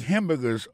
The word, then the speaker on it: hamburger: N'a pas remarqué que dans le mot hamburger, ça, comme t'as dit, il y a trois syllabes là-dedans. Côté appuyé, et puis plus ça, c'est sous premier syllabe là.